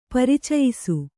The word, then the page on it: ಪರಿಚಯಿಸು: paricayisu